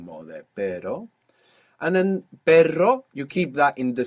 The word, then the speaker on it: perro: r-r-r-r English sound. But you pass a little bit of air over it and you let that flick it. Perro.